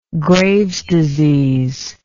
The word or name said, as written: graves disease